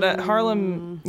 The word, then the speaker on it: Haarlem: I don't know how to pronounce it. Because it's Haarlem with two A's, but Haarlem, you know. – Haarlem. – Haarlem. – Oh, with the A's in the beginning? – Yes. – Like Haarlem. – Yes. – Got it. – That's how you say it.